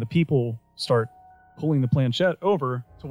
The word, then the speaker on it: planchette: they're holding the planchette or however you pronounce it. – Yep, planchette.